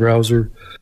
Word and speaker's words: Brauser: My name is Akeem Browser. Last name is spelled B-R-A-U-S-E-R, and I live on York Avenue since 1991 with my spouse who just spoke.